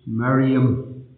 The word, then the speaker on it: Miriam: But the Greek is Maria. The Hebrew proper pronunciation is Miriam.